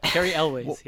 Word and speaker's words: Cary Elwes: So for Star-Lord, I went with a 90s star of some of my favorite movies, and that is Cary Elwes. – Yes, I think that's how you pronounce it. – Cary Elwes, yeah.